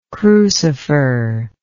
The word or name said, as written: crucifer